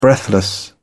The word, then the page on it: breathless: British English